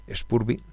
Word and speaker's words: Spurv: Pero hubo una banda que no conocía, que no había visto en directo tampoco, y que se llama Spurb, con S líquida y terminado en V, Spurb, Spurby, como se pronuncia.